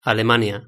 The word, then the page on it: alemania: Spain Spanish